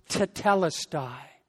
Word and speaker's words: tetelestai: The word in the Greek language is pronounced tetelestai.